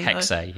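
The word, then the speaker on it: Hexe: Hex. – Is it actually pronounced Hex-A? – Hex-A, yes.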